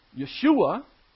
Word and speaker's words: Yeshua: I like to pronounce it probably the way that they did. Yeshua is translated into English as Jesus. But the Hebrew is Yeshua, and that's translated into the Greek as Iesu, which translates into the English, Jesus.